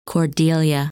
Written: Cordelia

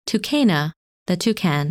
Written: Tucana

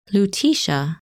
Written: Lutetia

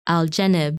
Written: Algenib

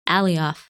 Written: Alioth

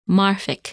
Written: Marfik

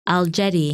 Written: Algedi

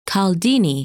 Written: Chaldene

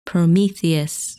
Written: Prometheus

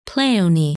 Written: Pleione

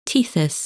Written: Tethys

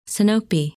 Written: Sinope

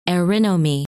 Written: Erinome

